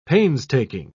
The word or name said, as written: painstaking